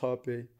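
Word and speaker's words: Rapé: It's spelled R-A-P-E, but it's pronounced Hape.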